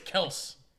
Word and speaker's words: Kelce: Oh, no, by the way, it's Kelce. – Kelce. – It's not Kelce. Kelce. It's Kelce. – It's Kelce. – Been saying it wrong TikTok.